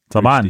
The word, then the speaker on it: Salman: Salman. Rushdie. But it's Salman.